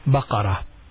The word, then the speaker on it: بقر: Not باقر. No, not باقر. It's close but not باقر. You need to pronounce it correct. There are actually three different plurals for this word. Baqara. – Bikrun. – No, not Bikrun. – No. – It is Baqar.